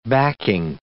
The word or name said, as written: backing